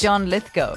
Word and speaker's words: Lithgow: It's John Lithgow.